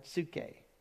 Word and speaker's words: psuche: And then there's the word psuche. Isn't that a cool word? Psuche.